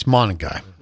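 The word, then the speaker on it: Monangai: Is that how you say it? Monagai? – That's pretty good. – Bruntz was really focused on this, and I just referred to him as the Rutgers running back. Yeah. – What does Bruntz say? – Well, he can actually say the name. I can't. – Okay, it's Monagai. – I think that's how it's pronounced.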